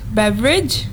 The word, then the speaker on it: beverage: So actually, it is beverage.